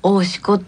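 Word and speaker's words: 生石: 生まれる石の神社って書くんです、はい、それで大志子って読むんですけど普通では読めません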